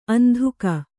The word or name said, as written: ಅಂಧುಕ